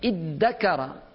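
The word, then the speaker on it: ادّكر: But iddakara, even if you don't know Arabic, this is iddakara. It's very, even when you pronunciate it, it's very difficult. That's always in Arabic, if the pronunciation is difficult, means more emphasis. It's not just tadakkar. It's easy to say it.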